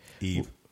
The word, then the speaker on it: EVE: Eve. – What? – The word is pronounced Eve.